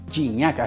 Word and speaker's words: Gignac: Eh, aquí lo importante es el gol que hace André Pierre Gignac. Gignac, así pide él que le, le pronuncie el nombre.